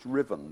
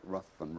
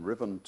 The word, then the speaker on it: Ruthven: On the second page of the handout, I've given you a poem about the Second World War by a Scottish writer called, that first name is pronounced Riven, though it looks like Ruthven, Riven Todd.